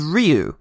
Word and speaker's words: Ryu: Meanwhile, Ryu and Ken, and it's worth pointing out, his name is Ryu. That's how you pronounce the Japanese name Ryu.